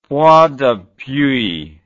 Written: point d'appui

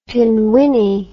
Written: pinwinnie

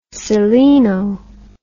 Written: seleno

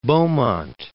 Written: Beaumont

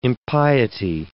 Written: impiety

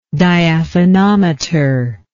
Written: diaphanometer

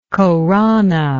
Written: Khorana

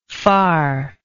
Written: farness

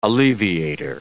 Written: alleviator

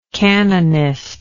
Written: canonistical

